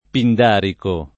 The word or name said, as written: pindarico